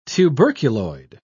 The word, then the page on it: tuberculoid: British English